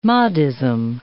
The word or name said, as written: Mahdism